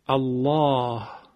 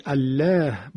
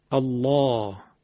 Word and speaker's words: الله: حالا ببینید، مثلا لام رو من ما قبلش فتحه آمده اینطور تلفظ میکنم، الله الله. حالا مثلا شاید فکر میکردیم که الله باید گفته شه، نه، باید غلیظ بشه، غلظتش هم از تو حلق ایجاد میکنیم، الله، نمیگیم الله، یا مثلا هو الله یا نصر الله، قبلش ذمه آمده، نصر الله رسول الله.